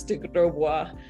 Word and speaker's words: de Grosbois: It's pronounced the French way. It's de Grosbois.